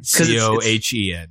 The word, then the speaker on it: Cohen: C O H E N.